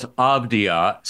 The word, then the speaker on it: Avdija: The names he had on this list were Kobe White, Denny Avdija, and Isaiah Hartenstein. So that leads to this question. Have you heard of any of these people? – Well, first of all, it's Avdija. Second of all, it's Hartenstein.